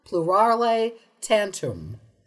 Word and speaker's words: plurale tantum: Plurale tantum. – It's hard to pronounce the plurale part, but yes, you're right. – Yeah, you want to do pru.